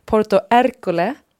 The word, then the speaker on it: Porto Ercole: Och vi var också besökte det här som du tipsade om, Sofie, Porto Ercole Uttalar man det så? – Ja, mm.